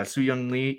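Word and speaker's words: Sun-Hyung Lee: Soo-Young Lee.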